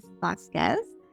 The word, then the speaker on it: Vasquez: You know, and I'm, I'm amazed that you said that better than most Americans pronounce uh, my, my maiden name is Vasquez.